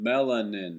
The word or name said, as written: Melanin